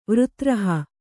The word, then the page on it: ವೃತ್ರಹ: kannada